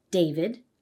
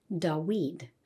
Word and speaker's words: David: So, for example, in modern Hebrew, the name of David, which has a V sound, you'd pronounce it as David. But in ancient Hebrew, it probably sounded more like Dawid.